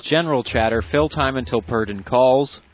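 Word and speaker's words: Purden: He pronounces it Purdon.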